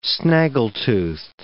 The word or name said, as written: snaggletoothed